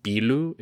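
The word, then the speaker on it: pillu: It's a short one, and it says the Wekufe also have the power to capture and enslave the Biu. This could also be pronounced Bilu. It's P-I-L-L-U,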